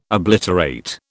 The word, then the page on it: obliterate: British English